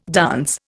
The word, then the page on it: dance: Indian